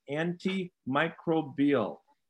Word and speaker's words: antimicrobial: I was pronouncing it wrong all these weeks that Iron Jock has been our great sponsor. But it's antimicrobial is the pronunciation